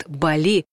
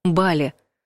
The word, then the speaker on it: Бали: А если не поправят, все-таки не очень вежливо говорить об ошибках, то точно подумают: «А, грамотеи!» Действительно, большинство россиян произносит слово с ударением на «и», как «жалюзи», но вариант «Бали» в русском языке считается неверным. Словарь имен собственных Агеенко дает один нормативный вариант – «Бали».